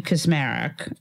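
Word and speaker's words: Kacsmaryk: is Judge Matthew Kaczmarek. I think I said that right. – Mm-hmm. You did.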